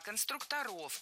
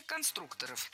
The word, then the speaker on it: конструкторов: А вот именно конструкторская команда, ну вот навскидку, это сколько было человек, вот отряд конструкторов? – Конструктора, конструкторов, это неверно. В слове «конструктор» ударение должно сохраняться на втором слоге, на «у». Поэтому конструкторы, конструкторов.